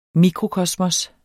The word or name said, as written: mikrokosmos